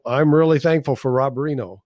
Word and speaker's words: Rienow: We probably ought to tell people, you know, he spells his name R-I-E-N-O-W, but it's pronounced Reno. And people that see the name don't often pronounce it right the first time. We want to help him by getting it right. – It's not Rhino. – It's not. No. No. – No, it's Reno. And although it's not like Reno, Nevada.